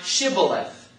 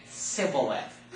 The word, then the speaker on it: Shibboleth: Then said they unto him in verse 6, say now Shibboleth. Okay? And he said, Sibboleth. For he could not frame to pronounce it right.